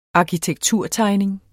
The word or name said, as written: arkitekturtegning